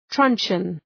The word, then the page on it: truncheon: ‘trʌntʃən